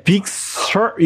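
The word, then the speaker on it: Big Sur: 빅서. – 빅서. – 이게 빅서 이게 R 발음이 들어가긴 들어가요. 그래서 빅서가 될 수도 있고요.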